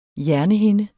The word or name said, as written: hjernehinde